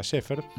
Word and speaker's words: Schaeffer: Ejemplo, primer ejemplo de música concreta de Pierre Schaeffer. Lo pronuncio así porque era francés. Aunque el apellido sea alemán, que sí. Es como la lapicera Schaeffer, lo pronunciamos Schaeffer.